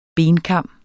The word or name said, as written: benkam